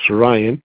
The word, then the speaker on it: Riann: She lives with her husband, R-I-N-N, with her daughter, R-I-A-N-N, pronounced Ryan.